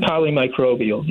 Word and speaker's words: polymicrobial: We're talking about Pope Francis, who is in the hospital, not feeling well, with a polymicrobial, I think I'm pronouncing it right, did I say it right? Doctor? – Polymicrobial, yes. – Polymicrobial infection.